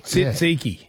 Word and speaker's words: tzatziki: Tzatziki.